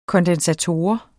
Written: kondensator

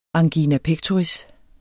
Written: angina

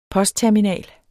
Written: postterminal

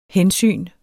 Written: hensyn

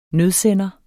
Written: nødsender

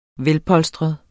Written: velpolstret

